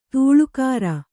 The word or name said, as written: ಧಾಳಿಕಾರ